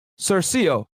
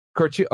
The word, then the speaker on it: Curcio: Executed by Detective John Cercio. Yo, you know what? John Cercio or Curcio. You're Italian. Is that Italian right there? Am I pronouncing that right? – Curcio. – Okay, thank you.